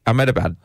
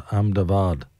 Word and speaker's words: Ahmedabad: I think I pronounced it correctly. Ahmedabad, I think you did. – Yeah. – Yeah. Locally branded as Ahmedabad.